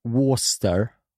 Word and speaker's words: Worcestershire: Worcestershire. Worcestershire. – Nej. – Vad säger du då, vad fan den heter? – Ja, men det stavas ju Worcestershire. – Ja. Men man uttalar det Worcester.